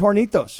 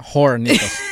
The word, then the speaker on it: Hornitos: This one's Hornitos, not Hornitos. Hornitos. – Oh, it's not Hornitos. – The H is silent.